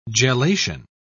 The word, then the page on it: gelation: British English